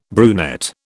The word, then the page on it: brunette: British English